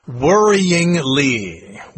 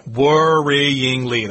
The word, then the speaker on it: worryingly: Worryingly. Worryingly. That's like 4 syllables.